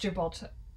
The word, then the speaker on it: Gibraltar: No, but he's British, so it sounds like an er, but it's Gibraltar.